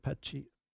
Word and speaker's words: Pesci: Joe Pesci is 81. – Pesci. It's S-H. – I know. I can never say that. Actually, it's P-E-S-C-I. – Yeah, I know, but like you say it like S-H.